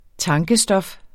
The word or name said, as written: tankestof